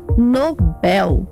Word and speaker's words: Nobel: A forma correta é Nobel. Se fosse Nobel, teria acento no O. Seria uma paroxítona terminada em L. As palavras paroxítonas terminadas em L são acentuadas, como nível.